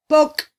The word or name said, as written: pok